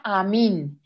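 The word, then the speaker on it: Amen: Se escribe como amén, como cuando dices amén después de una oración, pero se pronuncia Amin.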